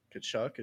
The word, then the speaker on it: Tkachuk: I think one guy who's going to play a big role is Tkachuk.